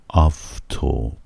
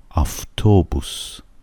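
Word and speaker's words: آفتوبوس (автобус): دومی صداش اینجا در واقع و هستش، ولی شبیه ف تلفظ میشه اما ف نیست، و هست. آفتوبوس، آفتوبوس، آفتوبوس.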